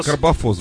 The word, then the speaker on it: Карбофос: Да, или Карбофос! – Как... А, Карбофос, блин, я их, конечно, ударение путаю. Карбофос!